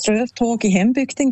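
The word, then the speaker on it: Strövtåg i hembygden: Ich finde, du hast die Aussprache super hergebracht. Das Lied heisst Ströftag in Hembügden, genau.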